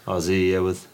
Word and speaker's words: Aussie: Aussie, yeah. It's spelled with an S, but you say Aussie. – With a Z or Z.